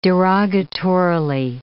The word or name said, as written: derogatorily